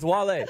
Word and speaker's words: Wale: Wale. – No, that's how I call him Whale. You know I call him Whale. – You keep calling him Whale. It's Wale.